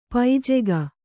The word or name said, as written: ಪೂಜೆಗೈ